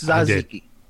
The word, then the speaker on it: tzatziki: I'll pronounce it right. It's tzatziki.